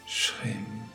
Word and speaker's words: Shrim: Un pas après l'autre, jour après jour, et préparez-vous pour la méditation, le mantra pour la graine d'abondance se prononce Shrim, SH, qui forme le son SH, RIM, SHRIM. Shrim.